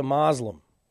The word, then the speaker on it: Muslim: Yeah, I prefer the British pronunciation, if you don't mind, Marianne. Muslim. He's a Muslim? That's the way Churchill said it, so that's the way I'm going to say it.